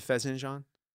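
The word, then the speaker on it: fesenjan: Do you like the fezenjan? Is that, am I pronouncing that? – Yeah.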